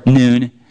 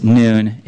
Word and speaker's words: Nun: You could pronounce it Noon if that's confusing to you. Like, he did have a father. He wasn't the son of none, like no one. He was the son of Noon.